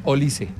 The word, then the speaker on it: Olise: Mi apellido es de origen nigeriano, etcétera, tal. La correcta pronunciación es Olise.